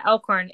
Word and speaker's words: Elkhorn: Talk to me about your hometown of is it I don't want to pronounce it badly, but is it Elkhorn, Manitoba? – That's correct. Yeah, Elkhorn.